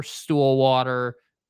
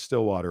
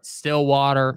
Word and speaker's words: Stillwater: Every football team, whether you're in San Francisco or Philly or Stillwater, goes through stuff. – You know it's pronounced Stillwater, right? – Oh, my bad. Sorry. Stillwater.